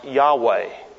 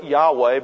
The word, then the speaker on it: Yahweh: Now, the vowels aren't known for certain, but most scholars believe that the name is to be pronounced Yahweh.